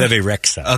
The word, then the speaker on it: Bebe Rexha: Is it Bebe Rexha? – Bebe Rexha.